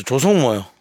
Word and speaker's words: 조성모: 발음 좀 잘해주시죠. – 조성모요. 네, 조성모요. 소송모. 아 소성모라니요.